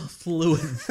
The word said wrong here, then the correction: Thewlis: T-H. It's the and then W-L-I-S. I'm guessing. You definitely know. – Oh, Thewlis. – Yeah, I said it wrong.